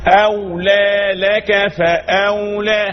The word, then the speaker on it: أولى لك فأولى: أولى لك فأولى، هكذا النطق الصحيح، أولى لك فأولى.